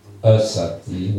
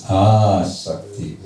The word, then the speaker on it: asakti: This leads to asakti. Make sure it's a long A if you're speaking about this. It's not asakti, which would mean not attachment. This is asakti, which means firm attachment.